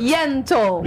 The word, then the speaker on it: Yentl: Ó, a querida Alessandra Maestrini, ela tá em peça, ela tem peça, que é Ent in Concert. – Yentl. – Eu vou fazer com pronúncia Yentl in Concert.